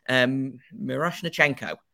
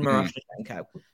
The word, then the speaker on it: Miroshnichenko: But like, do you take, you know, someone like Ivan Miroshnik? I always butcher his name. I do apologize. Miroshnichenko. Ivan Miroshnichenko, who I believe went in the 15 to 20 range last year.